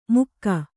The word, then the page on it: ಮುಕ್ಕ: Kannada